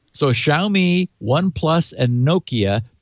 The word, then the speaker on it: Xiaomi: And also OnePlus, Nokia, and is it pronounced Xiaomi? – Xiaomi.